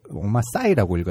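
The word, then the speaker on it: Omar Sy: 배우 또한 언터처블에서 드리스로 나왔던 오마 사이가 그 오마 사이라고 읽어야겠네요.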